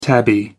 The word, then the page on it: tabby: American English